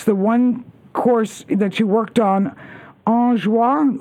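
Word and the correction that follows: En-Joie: How do you pronounce that in American? – I'm sorry, which one? – The PGA and Champions Tour host. ENJOIE. – Oh, that's called Enjoy. – Enjoy.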